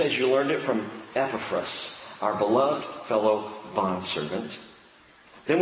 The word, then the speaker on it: Epaphras: There's a correct pronunciation, and I think we owe it to this man to pronounce his name correctly. It's not Epaphras, it's Epaphras.